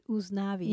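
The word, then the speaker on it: Usnavi: So in Spanish, you pronounce it Uznavi.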